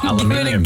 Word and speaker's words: aluminium: Oh, aluminium. – Give it a go. – Aluminum. – Aluminum. – Americans say aluminum.